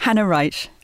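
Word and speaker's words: Hanna Reitsch: First of all, Claire, did I pronounce their names right then? – No. – No, I didn't think so. How should they be pronounced? – Hannah Wright and Melita von Stauffenberg.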